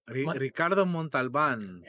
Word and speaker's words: Ricardo Montalban: Ricardo Montalban.